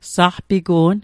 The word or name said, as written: saqpigu'n